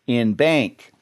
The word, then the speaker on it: en banc: That was the term en banc, or as some people say, in bank. And we're going to be talking about some en banc